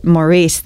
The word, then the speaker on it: MÅURICE: So the A. So it's a Norwegian A, and it's the omelok, and that you still pronounce it Maurice, thankfully.